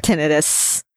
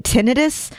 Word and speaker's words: tinnitus: Tinnitus. Technically, most doctors pronounce it tinnitus, but everyone else says tinnitus.